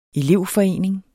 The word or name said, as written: elevforening